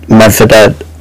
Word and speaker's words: Medvedev: Like, you look at in tennis with Medvedev, or is that how you pronounce it?